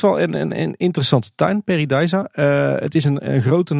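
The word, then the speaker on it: Pairi Daiza: Maar het zijn echt de Nederlandstalige Belgen die zeggen Peridiza. Dus dat is hoe je het blijkbaar echt moet uitspreken.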